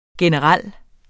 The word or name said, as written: generel